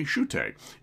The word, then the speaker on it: Shutta: Uh, S H U T T A, but pronounced apparently Shute.